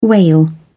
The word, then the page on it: wail: British English